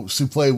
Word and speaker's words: souple: Also, souple would be the proper pronunciation. – Yeah. – Yeah. I mean, that's just, if you know how words are read in French, that's just how it works, you know?